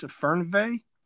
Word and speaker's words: Fernweh: and I think that's pronounced Fernweh. – That's the German. Yeah, good job.